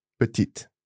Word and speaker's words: petit: Petite. Now, I'm sure I've heard petite. – Again, some regions might overpronounce, in my opinion, okay. – pronounce a final E, but other than that, it's just the T that's pronounced here. Yeah. So petit, petite.